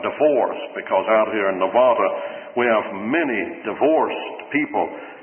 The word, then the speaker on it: Nevada: I heard someone the other day keep saying over the radio, Nevada. Or maybe those Nevadans pronounce it that way, but it's really Nevada.